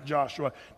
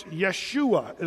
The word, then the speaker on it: Yeshua: Did you know the Hebrew people didn't even have a J sound in their pronunciation? So the J was always a Y in the Hebrew. So it's Yeshua is the way they would say it.